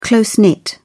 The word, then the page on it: close-knit: British English